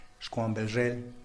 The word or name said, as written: xkwanbezhel